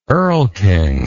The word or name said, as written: erlking